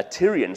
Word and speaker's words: Tyrian: You had to use something called a Tyrolean shekel. Sorry, Tyrolean, I said that wrong. A Tyrian shekel.